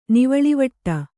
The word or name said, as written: ನೀವಳಿವಟ್ಟ